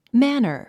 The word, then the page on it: manor: mǽnər; マァナァ